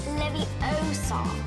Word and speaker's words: Leviosa: You're saying it wrong. It's Leviosa.